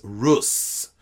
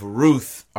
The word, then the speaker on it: Ruth: Now, in English, that is translated to the book of Ruth, R-U-T-H. But in Hebrew, if you are Svaldi, it is Rut, Ruti, Rut. But in Ashkenazi pronunciation, it is Rus.